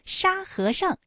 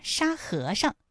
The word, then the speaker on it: 沙和尚: 沙和尚这三个字，他们的声调分别是一声、二声和轻声。特别注意，沙和尚，哎，在这里面最后那个上，它是读轻声。